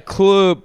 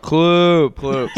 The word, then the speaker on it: CL30P: It's kind of Kloop, but the three is silent. – Yeah, so we don't really know how to pronounce it. – Thank that. – You for the pronunciation. – You add an extra O. Kloop. Silent three.